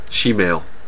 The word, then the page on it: shemale: /ˈʃi.meɪl/; American English